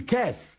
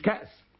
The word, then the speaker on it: كأس: أبو جعفر المدني، سواء فاء كلمة عين كلمة لام كلمة مش هينطقوا، فيقول ايه مؤمنون، ويقول بير مش بئر، ذيب مش ذئب، يقول كاس مش كأس، تمام؟